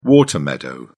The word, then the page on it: water meadow: British English